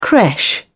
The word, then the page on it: creche: British English